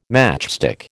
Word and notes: matchstick: American English

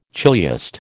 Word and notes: chiliast: American English

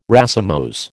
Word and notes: racemose: American English

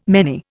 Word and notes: many: Indian

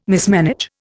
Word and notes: mismanage: Indian

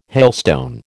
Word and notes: hailstone: American English

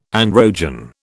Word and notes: androgen: British English

